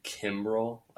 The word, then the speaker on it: Kimbrel: Like, isn't it Craig Kimbrell? Like K-I-M-B-R-E-L.